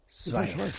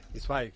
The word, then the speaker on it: Zweig: Stefan Zweig. – Se ha esforzado. – Díselo. – Lo ha dicho bien. – ¿Cómo es? – Zweig.